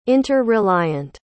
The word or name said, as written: inter-reliant